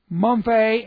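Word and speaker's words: mumphe (μομφή): The Greek word, mumphe, M-O-M-P-H, long E, it means to, listen, the word means to find blame or fault.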